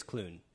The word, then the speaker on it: Klune: I want to say it's Klune. It's K-L-U-N-E.